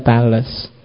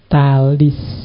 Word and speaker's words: Thales: Ya, kalau bahasa Inggris juga gitu, kalau pakai dialek Inggris kadang memang dibaca Tales. Tapi pakai lidah Yunani sebenarnya bacanya Talis.